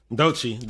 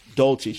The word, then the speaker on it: Doechii: Dolce? – Dolce. D-O-E-C-H-I-I. – Dolce.